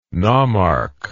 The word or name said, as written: nomarch